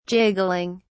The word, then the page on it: jiggling: British English